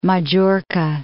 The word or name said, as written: Majorca